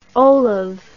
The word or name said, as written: olav